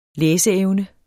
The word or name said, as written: læseevne